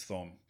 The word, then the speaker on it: Thom: T-H-I-M. – People call him Tom, and in situations where I would expect him to say, hey, no. My name is Thom. So it is, yeah, well, Tom. But yeah, Tom, spelled with the H.